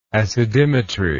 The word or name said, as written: acidimetry